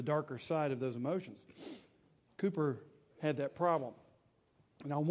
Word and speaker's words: Cowper: It's spelled C O W P E R, spelled like Cowper, but it's pronounced Cooper, William Cooper, uh, back in the 18th century and uh, 19th century.